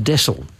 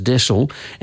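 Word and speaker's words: deasil: And someone got in touch with me, and they'd come across this quote that mentioned widdershins, and it's pronounced desil. It's D-E-A-S-I-L, but it's pronounced desil, and it means clockwise.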